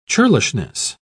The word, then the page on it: churlishness: British English